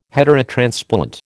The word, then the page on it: heterotransplant: American English